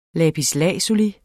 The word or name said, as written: lapis